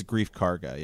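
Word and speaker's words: Greef Karga: What do you think about, how do you say his name? Grief? – I think it's Grief Karga.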